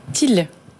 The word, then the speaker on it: Teale: Til. – Til, ça s'écrit ? – T-E-A-L-E. – T-E-A-L-E. Ça veut dire quelque chose, Til ? – Oui, tout à fait.